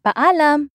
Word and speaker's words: paalam: It's paalam.